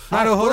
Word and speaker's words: Naruhodo: verdade, em japonês todas as sílabas são tônicas. Todas. É. Naruhodo. Naruhodô.